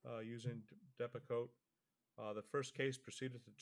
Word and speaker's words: Depakote: against the manufacturer of Depakote, is that how you pronounce it? Depakote. – Depakote, yes.